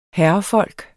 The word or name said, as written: herrefolk